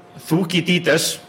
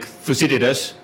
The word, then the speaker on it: Thucydides: It was Thukydides, that's the way you pronounce it in Icelandic, Thucydides, who said, you know, on the Peloponnesian War that the strong will conquer the weak and the weak just have to suffer.